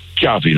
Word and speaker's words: Xavi: De beste vraag vond ik nog, hoe spreek je nou je naam eigenlijk uit? En toen zei hij, Xavi, like chocolate.